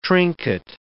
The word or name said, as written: trinket